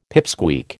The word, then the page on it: pipsqueak: American English